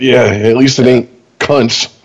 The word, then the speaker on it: Kuntz: It's pronounced, how many times do you think Rusty in his life had to say, it's pronounced Kuntz, thank you very much? – Every damn day.